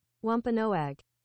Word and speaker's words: Wampanoag: Let's go to Google and see what Google says. – Oh, shit. You think Google will pronounce it for us? – What if this is what gets us canceled, mispronouncing Native American tribes? It's like, local podcast, number two podcast in Buckingham. All right, here we go. Slow down, everybody. – Wampanoag. – Wampanoag.